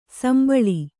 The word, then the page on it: ಸುಂಬುಳ: Kannada